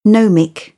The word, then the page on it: gnomic: British English; əʊ